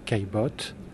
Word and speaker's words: Caillebotte: How do you say the name of the painter? – It's Gustave Caillebotte. – Caillebotte. – C-A-I-L-L-E-B-O-T-T-E, Caillebotte.